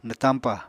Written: nwtañpà

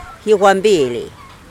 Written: gizh paporu

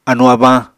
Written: anu-abañ

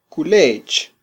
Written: kulech

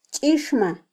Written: chixma

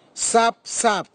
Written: sapsap

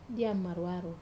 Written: dia maruaro

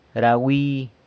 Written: ra wi

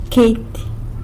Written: Queity

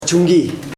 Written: chung.gi

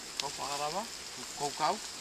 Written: kaufaghawara tu kokau

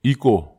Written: iko

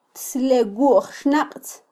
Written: tslewwujxnaqtz

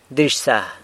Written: Dizhsa

